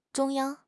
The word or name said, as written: 中央